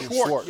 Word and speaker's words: Schwartz: And I think Jim Schwartz, am I saying his name right? Is it Schwartz or Schwartz? – Schwartz. Yeah, you got it.